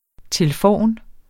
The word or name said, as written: tilforn